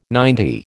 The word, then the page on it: ninety: American English